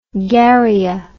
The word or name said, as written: garrya